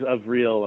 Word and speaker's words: Avril: Is it Avril? I've heard it Avril, and I've heard it Avril. Do you know how she says her name? – Yeah, it is Avril among her friends and colleagues.